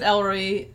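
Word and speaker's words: El Roi: So Elroyi, is that right? She's our pronunciation genius. – Don't go that far. I pronounce it Elroyi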